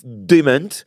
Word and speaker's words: DeMent: C'est une femme qui nous vient de l'Arkansas, qui s'appelle Iris Dement.